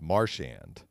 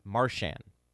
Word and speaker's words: Marchand: Yeah, but so it's Shand, and it's actually, the way I say it, you hit the first syllable, so it's Marchand. – Marchand.